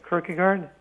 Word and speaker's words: Kierkegaard: Kierkegaard? – Kierkegaard, yeah.